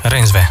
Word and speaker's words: Reinsve: cred că zici, Reinsve.